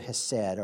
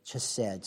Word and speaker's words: hesed: This Hebrew word for mercy here is the Hebrew word hesed, or chesed, some people pronounce it that way.